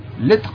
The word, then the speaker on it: Lettre: Otro de los títulos que van a sacar esta línea Gaijin de Ediciones Glenat es Letera. No sé si lo pronuncia bien, porque tiene una especie de capuchito encima de la E. – Lettre.